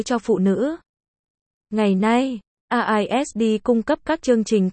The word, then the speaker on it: RISD: RISD, RZD, phát âm là RISD, là một trường nghệ thuật và thiết kế tư nhân ở Providence.